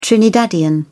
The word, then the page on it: trinidadian: British English